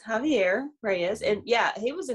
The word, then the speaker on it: Javier: But it's Javier Reyes did impress me. Mm-hmm. Yeah. – Oh, so we were saying Xavier Reyes. Were we saying it wrong? – Yes.